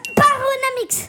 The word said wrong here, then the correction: Panoramix: Ouais, il y a une petite inversion de syllabe, mais peut-être que Paul va venir à... – Panoramix ? – C'est ça, c'était ça, voilà. Panoramix.